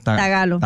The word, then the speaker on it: tagalo: Hay un lenguaje que se llama trágalo. – Tagalo. – No, tagalo.